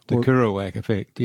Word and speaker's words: Kerouac: Kerouac Effect, yes.